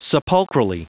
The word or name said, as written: sepulchrally